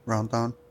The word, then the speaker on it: Rendon: I mean, with Rote, I don't know how to pronounce his last name right now. – Rendon? – Yeah, there you go. – Rendon? – That's what I was going to say.